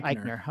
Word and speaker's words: Eichner: Eichner. – Eichner, okay.